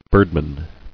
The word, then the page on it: Birdman: bird·man